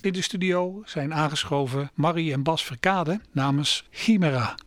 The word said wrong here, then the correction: Chimera: Spreek het goed uit? – Chimera. – Chimera. De klemtoon moet dus ergens anders.